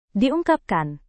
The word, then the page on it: diungkapkan: Indonesian